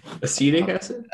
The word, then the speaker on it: acetic: For instance, the Bacillus species, they create lactic acid, acetic acid, I think is how it's pronounced. – Acetic acid? – Acetic acid.